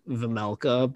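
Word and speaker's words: Vejmelka: I love that his last name is like Vemelka, but it's like it's spelled like Vegmelka.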